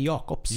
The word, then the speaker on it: Jakobs: Säger man Jacobs eller Jacobs? Jag kommer inte ihåg hur hon uttalar det nu. – Hon har ju ett K, så att jag säger Jakobs. – Jakobs, då säger vi Jakobs.